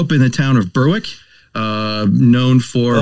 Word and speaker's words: Berwick: Yeah, pronounce it right. It's Berwick. – Berwick.